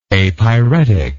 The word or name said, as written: apyrexia